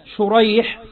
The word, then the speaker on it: شريح: قال: الأول بالشين المعجمة والحاء المهملة اللي هو شريح، وهو تابعي يروي عن علي رضي الله عنه.